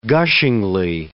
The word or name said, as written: gushingly